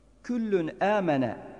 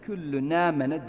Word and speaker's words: Küllün âmene: Küllün âmene kelimesini okurken küllü nâmene demeyelim. Küllün âmene şeklinde ayırt edelim orayı.